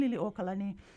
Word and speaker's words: Liliuokalani: You were on point with the pronunciation there for the birthday girl, Queen Liliuokalani. Yeah,